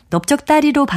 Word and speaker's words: 넓적다리: 넓적다리의 경우 릴 비읍 받침 중에서 뒤에 자음인 비읍으로 발음이 되니까요. 릴 비읍 받침을 그대로 적는 겁니다.